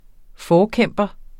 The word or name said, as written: forkæmper